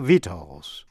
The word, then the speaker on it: victuals: Và shus, T-I-O-U-S, như trong chữ conscientious, là tận tâm. Và phần cuối là si câm, tức là không phát âm, như trong chữ vitals. Vitals, V-I-C-T-U-A-L-S, là thực phẩm, food or nourishment.